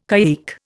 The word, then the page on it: caique: Indian